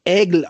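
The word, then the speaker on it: Aigle: Also, äh, es wird Ägel ausgesprochen, Eigle geschrieben, Ägel aus Frankreich.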